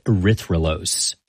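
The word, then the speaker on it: erythrulose: And they scanned it for radio signals of different molecules, which is how they found a four-carbon sugar called erythrolose. – I've heard of, like, glucose and fructose, but, like, how do you say that? – Erythrolose.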